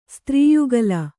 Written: ಸ್ತ್ರೀಯುಗಲ

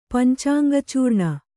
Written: ಪಂಚಾಂಗಚೂರ್ಣ

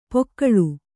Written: ಪಾಕಳ

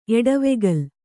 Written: ಎಡವೆಗಲ್

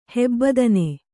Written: ಹೆಬ್ಬದನೆ

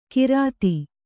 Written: ಘೃತ

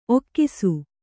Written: ಒಗಸು

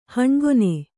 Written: ಹಣ್ಗೊನೆ

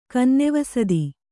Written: ಕನ್ನೆವಸದಿ

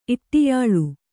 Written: ಇಟ್ಟಿಯಾಳು

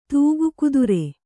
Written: ತೂಗುಕುದುರೆ

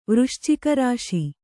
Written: ವೃಶ್ಚಿಕರಾಶಿ

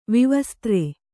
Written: ವಿವಸ್ತ್ರ

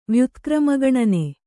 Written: ವ್ಯುತ್ಕ್ರಮಗಣನೆ

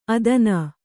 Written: ಅಧೀನೆ

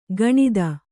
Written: ಗಣಿತ

